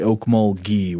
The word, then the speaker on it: Okmulgee: I think I said Oakmulgee. It's apparently Okmulgee with a hard G.